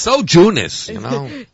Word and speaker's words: Jeunesse: I'm saying it right now, right? – Jeunesse. Yes.